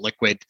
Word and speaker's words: LQWD: So, and our Liquid is spelled LQWD, the same as our Toronto Stock Exchange ticker. And so a lot of people just call it, they call it, hey, LQWD, we call it Liquid.